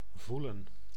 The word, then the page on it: voelen: /ˈvu.lə(n)/